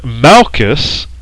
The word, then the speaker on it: Malchus: Now this word Malchus, which is spelled M-A-L-C-H-U-S, means king.